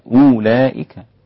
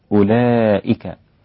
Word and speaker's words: أولئك: هذه الواو لا تنطق لأننا لا نقول أولئك. فلو أنها نطقت سيصير النطق الصحيح لها أولئك. إنما ليست هكذا إنما ننطقها أولئك.